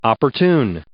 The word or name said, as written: opportune